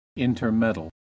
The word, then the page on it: intermeddle: American English